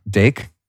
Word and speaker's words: DAKE: Something you talked about in the book is vitamin Dake, D-A-K-E. I don't know if you pronounce it that way. – How do you say it? – Dake? – Yeah, Dake.